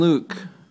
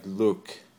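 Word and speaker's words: Luc: It's a French name. It's L-U-C. Well, it's more like Luke. But I don't really speak French. I'm from Minnesota, so I have trouble pronouncing it.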